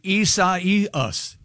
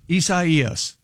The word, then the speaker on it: Isaias: Just to make it's like Osiasis. – That's very, very close. It's Hurricane Isaias. Isaias.